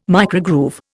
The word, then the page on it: microgroove: Indian